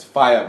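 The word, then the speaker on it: F.I.A.: It's Fire, bruh. It's not called Fear, bruh. – Let's go Fire. – Yes, nigga. Fire. It doesn't say Fear.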